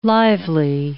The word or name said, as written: lively